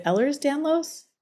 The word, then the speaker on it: Ehlers-Danlos: Is it Ehlers-Danlos? – Mm-hmm.